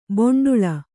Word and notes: ಬೆಂಡೇಳು: beṇḍēḷu

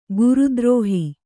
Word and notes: ಗುರುದ್ರೋಹ: guru drōha

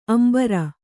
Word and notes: ಅಂಬರಿ: ambari

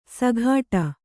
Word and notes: ಸಘಾಟ: Kannada

